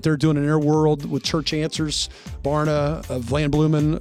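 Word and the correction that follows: Vanderbloemen: that's not how you say it. How do you say? – What's Vander Blumen? – Yeah, Vander Blumen. Thank you.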